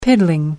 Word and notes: piddling: American English

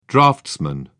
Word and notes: draughtsman: British English

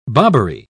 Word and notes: bobbery: British English

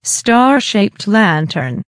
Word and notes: star shaped lantern: American English

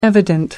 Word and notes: evident: American English

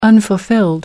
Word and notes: unfulfilled: American English

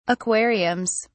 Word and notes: aquariums: British English

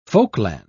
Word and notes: folkland: British English

